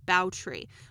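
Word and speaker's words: Báthory: Now, the English pronunciation of her name is Elizabeth Bathory, but her Hungarian name is Elsbeth Bautry.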